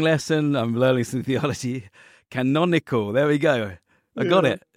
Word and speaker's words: canonical: I'm actually going to quote you something I read where you said, scholars can help songwriters discover the intertextual and canonical. How do you say that word? – Canonical. Yeah.